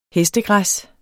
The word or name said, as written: hestegræs